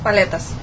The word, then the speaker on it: paletas: Is it paletas? – Yes, paletas.